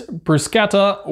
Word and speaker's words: bruschetta: And it is bruschetta with ricotta and peaches, which if you are Sicilian is bruschetta. And if you're from America, it's bruschetta.